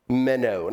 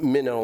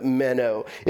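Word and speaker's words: meno (μένω): Jesus, now this word abide in the original Greek language, it's pronounced meno, not minnow like fishing, but meno.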